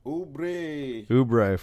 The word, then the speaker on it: Oubre: Small forward one, Kelly Oubre. Did I say that right? – Oubre from Phoenix.